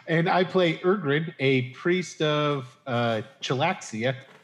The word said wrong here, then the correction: Chislev: No, that's not it. – How do you say it again, Adam? – Chislev.